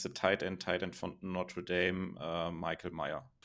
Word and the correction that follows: Mayer: Genau, also Michael Mayer. Natürlich. Mayer, ja, natürlich. Englisch ausgesprochen, ja.